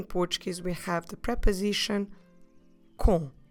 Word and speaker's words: com: C-O-M. Com. In order to pronounce correctly this preposition, don't forget to not close your lips at the end. It's a very nasal sound, but we should leave our lips open.